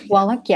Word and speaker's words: Wallach: Wallach, yes, I believe that's the pronunciation.